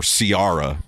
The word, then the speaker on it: Ciara: guys that didn't know music were calling her Ciara because of the way it's spelled.